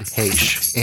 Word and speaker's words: Heche: I've always heard Heche, Anne Heche. Heche.